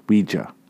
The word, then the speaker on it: Ouija: It says right here, pronounced in quotation marks, W-E-E-J-A, Ouija, Ouija board.